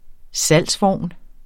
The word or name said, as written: salgsvogn